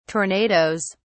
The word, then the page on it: tornados: British English